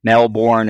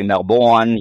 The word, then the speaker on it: Melbourne: Melbourne is actually Melbourne,